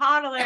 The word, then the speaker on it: hodler: You're saying it wrong. It's hodler. And, Just that's kidding.